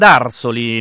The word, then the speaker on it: Arsoli: attenzione all'accento, andiamo ad Arsoli, non è vero sindaco? Do il benvenuto al sindaco di Arsoli, Claudio Bruni, buongiorno sindaco. – Buongiorno a tutti gli ascoltatori, buongiorno a voi. – Ho messo bene l'accento, sì? – Sì, sì, benissimo, benissimo. – Immagino che non pochi magari ci faranno scivolare l'accento sulla O, immagino.